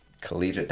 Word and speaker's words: collegiate: Collegiate. That's three syllables.